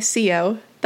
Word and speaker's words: Sio: To Borrowed Time by Sio. That's spelled T-S-I-O. The T is silent, the H is invisible, but the sound is quality.